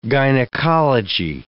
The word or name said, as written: gynecology